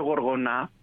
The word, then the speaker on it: Γοργονά: Και επίσης, μια και το ανέφερε, για να το συνδυάσουμε με το άλλο, έχουμε και μια άλλη ταινία, το Γοργονά. Έτσι τονίζεται, το οποίο, από ό,τι κατάλαβα, το οποίο είναι επίσης ταινία είδους.